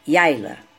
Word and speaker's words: Yayla: Se pronuncia como se escreve, Yaila.